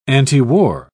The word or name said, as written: antiwar